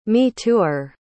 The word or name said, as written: me-tooer